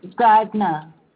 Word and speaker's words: Gardiner: Gardner. – May you please spell that for me? – G-A-R-D-I-N-E-R.